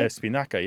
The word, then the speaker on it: espinaca: Spanish keeps it, though. Espinaca, right? – Espinaca, yeah. Spanish keeps it.